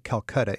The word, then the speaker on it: Calcata: And it sounds like Calcutta in India, but it's spelled C-A-L-C-A-T-A.